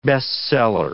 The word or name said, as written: best seller